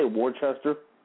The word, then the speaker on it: Worcester: Did you say Worcester? – Yeah. – It's not how you pronounce it. It's Worcester, not Worcester.